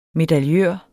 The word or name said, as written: medaljør